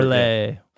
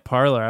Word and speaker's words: Parler: It's apparently a French pronunciation. Somebody was making fun of Ted Cruz for calling it Parler.